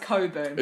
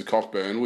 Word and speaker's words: Cockburn: It is spelt C-O-C-K-B-U-R-N. – That's pronounced Coburn. – It's got, well, you wouldn't go around calling yourself Mrs. Cockburn, would you? – So. No one's actually going to say Coburn. Everyone's going to say Cockburn.